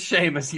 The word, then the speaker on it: Seamus: It looks like Seamus. I'm pretty sure it's pronounced – It is Seamus.